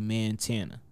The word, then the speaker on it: Mantana: And then around the time when all of these different Montanas started coming out, I changed I kept the spelling the same way, but I made the pronunciation actually Mantana.